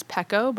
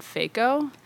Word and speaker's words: Pheko: From my last name, which is Pecko, but it's spelled P-H-E-K-O. Oh. And everyone always said Faco.